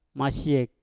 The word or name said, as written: masyek